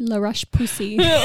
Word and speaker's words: La Roche-Posay: La Roche-Posay, if that's there. That's probably what I would go for. Is that how you say it? La Roche-Posay. Yeah, that is. I heard La Roche-Posay.